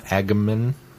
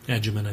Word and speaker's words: Agyeman: For Friday Night Who, this week we're going to be doing David Tennant and Freema Agyeman. – Agyeman, I think. – Agyeman. Mm-hmm. Is it soft G? – Agyeman.